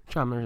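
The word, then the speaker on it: Chalmers: Well, below Aaron Chalmer, is that how you pronounce his name? – Chalmer, yeah.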